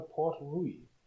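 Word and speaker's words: Port Ruighe: Wir trinken gerade ein Talisker Port Rui. So wird das angeblich ausgesprochen. Zumindest laut Horst Lönig von whisky.de.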